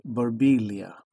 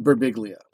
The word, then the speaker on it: Birbiglia: His joke is that he always would meet Italian people who would go, you know, in Italy, it's pronounced Birbiglia. And he'd be like, well, in America, you're annoying. So that's his response to that. So you nailed it with Birbiglia.